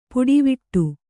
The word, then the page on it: ಪುಡಿವಿಟ್ಟು: puḍi viṭṭu